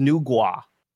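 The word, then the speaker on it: Nüwa: In Chinese culture, they believe that they were their bodies, that their race was created by a goddess named Nuwa, also kind of pronounced Nugua, like depending on how you pronounce it, which is very close to Naga.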